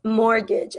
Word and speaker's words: mortgage: So it's mortgage. Again, this T is silent.